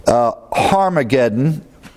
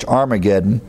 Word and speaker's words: Armageddon: It's pronounced in the Greek with a H, a little rough breathing. You add an H in transliteration, but Armageddon, if you want to be more accurate in the Greek pronunciation. We normally in the English, Armageddon, leave out the H.